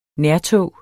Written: nærtog

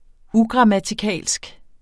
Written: ugrammatikalsk

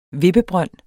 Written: vippebrønd

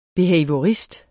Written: behaviorist

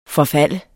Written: forfald

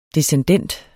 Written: descendent

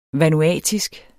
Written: vanuatisk

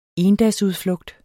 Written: endagsudflugt

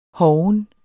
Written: hoven

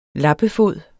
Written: lappefod

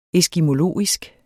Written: eskimologisk

